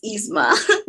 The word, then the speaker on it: Yzma: Is it like Yzma? – It's just Yzma. No Y.